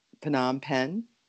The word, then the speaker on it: Phnom Penh: Because I always want to say Phnom for some reason. And I know that isn't right. – It's a P, yeah.